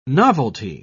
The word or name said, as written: novelty